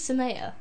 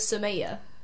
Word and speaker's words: Sumaiya: Like, why do you say your name is Sumaiya? Because that's not my name. My name is Sumaiya.